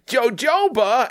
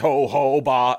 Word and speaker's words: jojoba: And this woman comes in and just goes, Jojoba! And this woman just she's got like the spray paint can in her hand. And she just snaps the camera and says, you said it wrong. It's Ho-Ho-Ba.